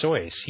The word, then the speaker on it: Seuss: It's his name is pronounced Seuss. He's the one who said that. He says It rhymes with voice.